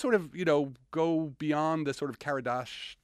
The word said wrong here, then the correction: Kardashev: if I can pronounce it right, the Karadashov. – Hold on, the Kardashev? – Kardashev, thank you.